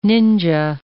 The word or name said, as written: ninja